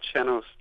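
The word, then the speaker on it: sean-nós: That's called Chenos dancing. – It looks like Sean, the way we usually spell a guy's name.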